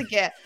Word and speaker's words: GIF: And I think is it gif? Do I pronounce it wrong? – I believe it's gif. – You believe it. Okay, yeah, good. – I think it's a hard G.